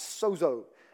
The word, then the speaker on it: sozo: It's a Greek word that we would pronounce sozo.